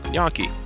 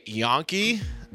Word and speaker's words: gnocchi: I used to say Yonki. – Yeah. And I mean, even that one's a little bit better than like Ganakis.